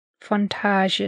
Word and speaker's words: Fontanges: See, that's what I thought, but it's the N that's Fontages. – I think it's Fontage. – So the N is silent. What is... French is a stupid language.